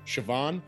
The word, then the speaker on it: Siobhan: That's the only reason I know how to say the name Siobhan, that is spelled S-I-O-B-H-A-N. – Yep. – That's Siobhan.